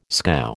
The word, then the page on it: scow: American English